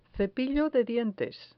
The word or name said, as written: cepillo de dientes